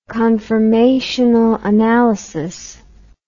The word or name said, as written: conformational analysis